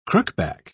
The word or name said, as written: crookback